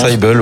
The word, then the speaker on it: Crucible: Un autre jeu dispo gratuitement sur Steam, c'est Crucible, je ne sais pas comment ça se prononce. – Crucible, ouais, mais c'était bien tenté.